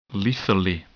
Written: lethally

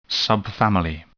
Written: subfamily

